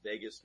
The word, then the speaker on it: vagus: Is it vagus? Mike, I hate to interrupt. Is it called vagus nerve? How do you spell it? – V-A-G-U-S. – V-A-G-U-S. – Okay. Vagus. – Okay. – It's like Las Vegas.